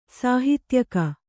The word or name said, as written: ಸಹೋದ್ಯೋಗಿ